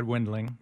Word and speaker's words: Wendling: It was close. Brad Wendling. – Wendling. – Two syllables.